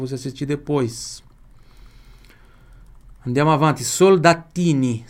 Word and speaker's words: soldatini: Duas pronúncias que você vai ter que prestar atenção aqui. No L. Porque L tem som de L na língua italiana. Ah, mas você vai dizer assim, sempre? Não, lógico, não. Em português, não. Em português, L tem som de U. Soldatini. E a pronúncia pura do T aqui, ó.